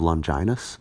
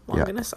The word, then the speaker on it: Longinus: I always thought that was pronounced Longinus as a kid. – Well, it depends, because hard G, soft G. – Just which Latin I'm using. – Yeah, well, classical Latin is hard G, I think, and soft G is the church Latin.